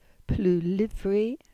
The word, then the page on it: Pluwlyvri: Cornish